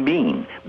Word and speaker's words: been: I just did a sheet of paper and took words from the script and said, you know, if you have the word Ben, B-E-E-N, say it like it's a green bean, B-E-A-N.